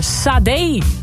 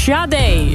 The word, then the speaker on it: Sade: Jij had het over Sade, maar die naam spreek je uit als Sade.